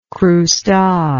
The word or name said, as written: croustade